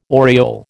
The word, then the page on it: oriole: American English